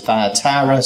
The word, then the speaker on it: Thyatira: I want to say Thyatira, and I looked it up, and most people pronounce it Thyatira, so that's what I went with, or I'm trying to go with.